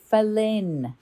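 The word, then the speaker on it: Phalène: It's Falen. It's spelled P-H-A-L-E-N-E. It's French and it means night moth.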